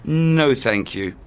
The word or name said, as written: no thank you